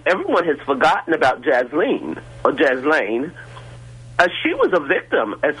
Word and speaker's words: Ghislaine: I just had, I'm a bit curious about, you're talking about Jeffrey Epstein, and Jasmine Maxwell, Ghislaine, I think her name is pronounced, Ghislaine Maxwell, has been languishing in jail for a crime that wasn't committed, or wasn't committed.